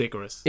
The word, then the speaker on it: Icarus: It is, there's something really interesting in it, though, because if you watch the documentary Icarus or Icarus, however you want to pronounce it, and Pronounced Icarus. – Icarus, yeah. – 100%, I've never heard anyone call it Icarus.